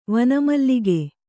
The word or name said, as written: ವನಮಲ್ಲಿಗೆ